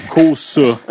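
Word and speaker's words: Cossa: Euh, faut être le plus québécois possible quand on prononce son nom de famille. Cosa.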